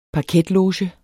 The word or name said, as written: parketloge